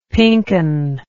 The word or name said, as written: pinken